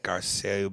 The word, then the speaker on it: Garcelle: Garcette, Garcelle Buvo.